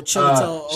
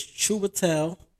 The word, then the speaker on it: Chiwetel: Oh, Chiwetel. – Shawito. Yeah, I can't ever say his name. – It's Chiwetel.